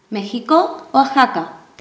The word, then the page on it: mexiko: Spanish